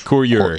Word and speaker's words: courier: Courrier, Redwin demande dans la chatroom si courrier se prononce aussi courrier en anglais. En fait, c'est courier.